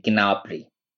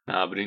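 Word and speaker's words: Gnabry: آره یکی از چیزایی که زبان آلمانی داره انها حرف بی صدا پشت سر هم تلفظ میشه مثلا به این ما میگیم گنابری درست نابری نیست گنابری خب،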